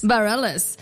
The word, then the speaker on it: Bareilles: Bareilles.